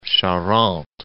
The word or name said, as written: Charente